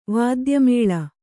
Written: ವಾದ್ಯಮೇಳ

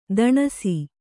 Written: ತೊಣಸಿ

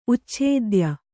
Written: ಉಚ್ಛೇದ್ಯ

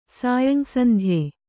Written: ಸಾಯಂಸಂಧ್ಯೆ

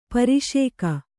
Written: ಪಾರಶಿಕ

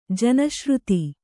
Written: ಜನಶ್ರುತಿ

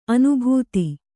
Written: ಅನುಭೂತ

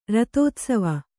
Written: ರಥೋತ್ಸವ

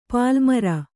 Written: ಪಲ್ಲುಮೊರೆ

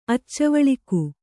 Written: ಅಚ್ಚುವಳಿಗ

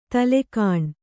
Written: ತಲೆಗಾಣ್

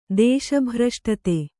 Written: ದೇಶಭ್ರಷ್ಟತೆ

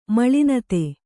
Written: ಮಲ್ಲಿನಾಥ

